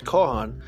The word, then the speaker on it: Chauhan: And we'll go through those things, because I have a feeling that the term Chauhan, it can be pronounced Kohan,